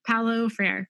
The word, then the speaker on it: Paulo Freire: Related, I recently read this quote, and it's by, I'm going to probably mispronounce the name, Paulo Freire, that says, sure. No, do you know? – Yeah, Brazilian. Yeah. – Okay. Okay, great. Paulo Freire.